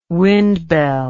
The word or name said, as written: windbell